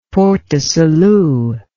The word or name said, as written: Port-du-Salut